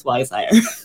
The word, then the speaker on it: WizeHire: And you're not the first person who's called it that, but yeah, it's WizeHire.